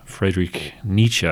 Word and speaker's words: Friedrich Nietzsche: And the person that you attribute that role to is Friedrich Nietzsche.